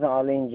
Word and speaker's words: Arlene: Can you spell it for me? – Arlene. – ARL. – ENE. – So it's Arlene.